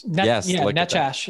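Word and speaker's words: Nečas: The other one, Marty Nekas. I think I'm saying that right. Am I saying that right, Frank? – Yes. – Nekas.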